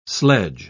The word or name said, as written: sledge